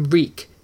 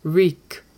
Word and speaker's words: Reek: Reek, Reek. It rhymes with weak."